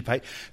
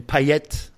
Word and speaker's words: Payet: I love Dimitri Payet. So we can. A lot of people pronounce his name Payet. – Payet. – Both are good.